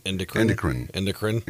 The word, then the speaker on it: endocrine: Another 22 were hormone or endocrine. Did I say that right? – Endocrine.